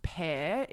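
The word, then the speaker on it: Paire: The brand is called, I think it's pronounced Pair. It's P-A-I-R-E.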